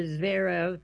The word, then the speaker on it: Zverev: Finally, in France, Alexander Zverev, that's Z-V-E-R-E-V, I hope I'm saying it right, Zverev, is no longer one of the best players never to win a major title.